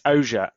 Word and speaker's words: Ogier: It's Ogier. – So Ogier. Okay. – It's French.